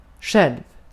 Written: öz

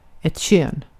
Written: kön

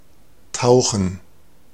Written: tauchen

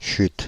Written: chut

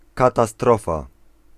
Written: disaster

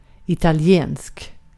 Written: İtalyan

